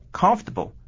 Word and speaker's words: comfortable: 这个字呢，它的轻重音要啊长短要分配一下，我们的重音节 com 要分多一点，我们念作 com 啊，然后呢 fer 要缩短，tab 音呢更要缩短，因为它是轻音中的轻音，所以整个的字我们是念作。 comfortable，哎，这样子呢就比较好听了，这是第一个问题。